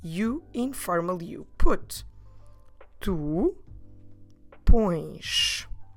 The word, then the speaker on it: pões: That's right, quite nasal. Oh, como se escreve pões? P O com til é S. So this verb form requires an accent, the accent that we call til. I'm talking about that little wave that always makes the vowel sounds nasal.